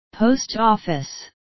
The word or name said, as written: post office